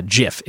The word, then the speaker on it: GIF: So Jif is the answer there. Also, Jif is how you're supposed to pronounce GIF.